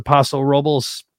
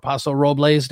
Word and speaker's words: Paso Robles: We did a 4-day long weekend, went up to Paso Robles, or Paso Robles, depending on how you pronounce it, it's north of LA, which is great wine country.